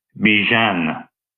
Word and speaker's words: Bijan: All right, anyway, I went to Falcons, select I think it's Byhan. Byhan? Robinson? I don't know how to pronounce that. How to pronounce. How to pronounce. I. How to pronounce. Pronounce the name by. What? No, that's not very helpful. Let's see here. I'm gonna click on this video. How to pronounce it? How to pronounce? – Bijan. – Oh, it's Bijan. It's Bijan.